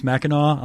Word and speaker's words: Mackinac: Audie talked about getting fudge from Mackinac Island, and that is Mackinac Island. I'm contractually obligated as a Michigan resident to mention that. And that's okay. It's spelled Mackinac.